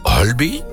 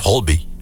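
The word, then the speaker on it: Høiby: In Noorwegen is er juist weinig reden voor feest, want Marius Borg Hulby, zeg ik dat goed, Rick? – Ja, heel goed. – Hulby. Is het Hulby of Holby? – Hulby. Nee, Hulby.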